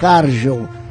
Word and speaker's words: Cargill: Na verdade, a pronúncia correta é Cargill.